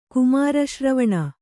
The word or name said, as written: ಕುಮಾರಶ್ರವಣ